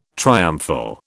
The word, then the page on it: triumphal: British English